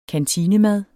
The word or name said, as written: kantinemad